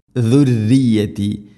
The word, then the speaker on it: ذريتي: എന്നാലും ദുർറിയത്തി പറയരുത്, തർക്കീഖ് ചെയ്യണം, നേർപ്പിക്കണം. ദുർറിയത്തി, ദാലിൻ്റെ ഉച്ചാരണം ശ്രദ്ധിക്കുക, ദുർറിയത്തല്ല, ദുർറിയത്തി.